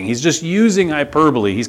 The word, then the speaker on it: hyperbole: Okay, now, this is a good example of what's called hyperbole. Those of you taking notes, kids, it's spelled like hyperbole, but it's hyperbole.